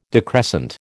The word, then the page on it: decrescent: American English